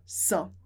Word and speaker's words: sans: Porque normalmente a gente fala sans, sem pronunciar o S. Sem. Mas com internet, que inicia com som de vogal, a gente vai acrescentar o som z.